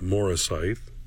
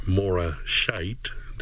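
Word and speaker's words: Moresheth: He was 20 miles south of Jerusalem in his place of Morasith, or Morashite, depending on how you pronounce it.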